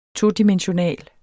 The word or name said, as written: TODIMENSIONALE